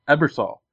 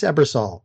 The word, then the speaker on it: Ebersol: like with Dave how do you say his name? – Ebersol. – Ebersol. – I believe it's Ebersol. If it isn't, I am sorry, Mr. Ebersol.